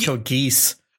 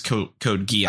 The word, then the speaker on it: Code Geass: I always said Code Geese. – Okay, so they call it a Geass in the show. – They do, yeah. – And that's why I know it's Code Geass.